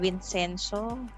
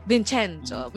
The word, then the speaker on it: Vincenzo: Ano ba pagbasa niyan? Vincenzo? – Bahala. Vincenzo. May ganun na ata siya kasi Italia.